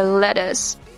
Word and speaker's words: lettuce: Okay, double T pronounced like D in American accent, and the letter U pronounced like uh. Okay, lettuce.